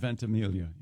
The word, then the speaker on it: Ventimiglia: Does he go with the soft G, or does he do the Mike Birbiglia thing? – I have heard the soft G. – I've heard Ventimiglia.